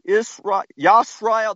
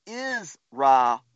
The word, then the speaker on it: Yisrael: He changed his name to Yisrael. Not Israel.